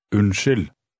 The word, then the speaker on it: Unnskyld: Unnskyld.